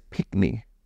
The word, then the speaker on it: pickney: It is pickney. P-I-C-K-N-E-Y, pickney.